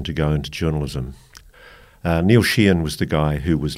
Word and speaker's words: Sheehan: Sheehan.